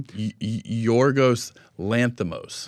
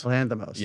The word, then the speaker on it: Lanthimos: Yorgos Lanthimos. – Lanthimos.